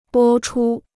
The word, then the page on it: 播出: bō chū